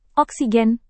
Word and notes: oksigen: Indonesian

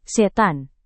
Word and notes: setan: indonesian

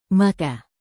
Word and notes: maka: Indonesian

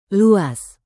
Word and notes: luas: Indonesian

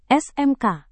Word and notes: smk: Indonesian

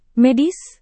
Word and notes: medis: Indonesian

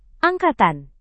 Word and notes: angkatan: indonesian

